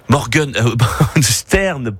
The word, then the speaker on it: Morgenstern: On en parle en plateau avec Suzy Morgenstern, écrivaine Morgenstern, pour la prononciation,